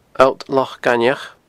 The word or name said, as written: Allt Loch Gainmheach